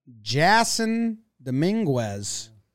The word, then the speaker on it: Jasson Dominguez: Jasson Dominguez. – You said that right.